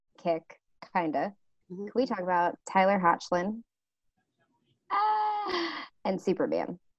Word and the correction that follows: Hoechlin: Okay, so first of all, it's Tyler Hoechlin. Oh, Hoechlin. Hoechlin. Okay, my bad.